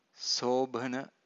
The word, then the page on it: sobhana: Pali